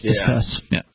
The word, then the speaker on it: Disqus: Their site, you know, on the commentary, because you have to go through something called Disquis, and Disquis has this elaborate feature. Discuss, is that what it's actually called? – Okay. D-I-S-Q-U-S. – It's pronounced Discuss.